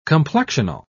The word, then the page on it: complexional: British English